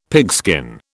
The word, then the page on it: pigskin: British English